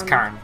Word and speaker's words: cairn: And it's Karn, like a carnival.